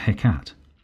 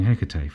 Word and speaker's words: Hecate: Hecate.